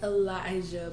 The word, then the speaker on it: Alicia: Isn't that how you... – It's Elijah, but in the book, y'all, it is spelled Alicia. Had I not kind of gone back and listened to some other excerpts, I would have been saying Alicia too and would have been highly confused. But it's actually Elijah.